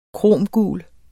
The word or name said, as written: kromgul